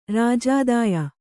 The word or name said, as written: ರಾಜಾದಾಯ